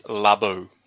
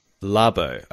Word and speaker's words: Labo: Officially, it is Labo. – Labo. Okay, Nintendo Labo. That is how we say it.